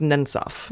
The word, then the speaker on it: Nemtsov: Yeah, it's pronounced Nemtsov.